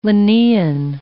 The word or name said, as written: Linnaean